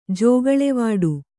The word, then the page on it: ಜೋಗುಳೆವಾಡು: jōguḷevāḍu